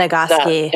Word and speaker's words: Nagoski: like we discovered in Emily Nagoski, am I saying that right? – Mm-hmm. Nagoski.